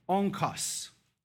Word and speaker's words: onkos: Now that's what he says here, but this word here for weight is the Greek word that's right here, all right? And it's pronounced onkos, all right?